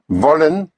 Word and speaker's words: wollen: Wollen is spelled W-O-L-L-E-N. Again, the W has the sound of a V. Wollen. Wollen Sie. Sie means you. Spelled capital S-I-E.